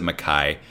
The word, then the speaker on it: MacKay: And George Mackay, spelled McKay, but I think he pronounces it Mackay, who I only really knew from Captain Fantastic, which he's very good in as Viggo's oldest son.